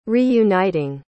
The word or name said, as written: reuniting